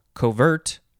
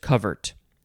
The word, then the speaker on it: covert: You could also just emphasize the second syllable, covert. I guess it might depend on the context. And then you could also just say covert. Just covert.